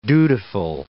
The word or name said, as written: dutiful